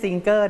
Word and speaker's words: singer: เด็กๆครับไม่ใช่ singer นะ no ไม่ใช่เสียงเกอนะครับ ng ออกเสียงเงอเพราะฉะนั้นแล้วออกเสียงว่า singer 5 ครั้งครับ